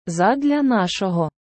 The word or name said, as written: Задля нашого